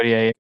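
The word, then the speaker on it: Gotye: Gautier, is that how you say it? – Yeah, Gautier.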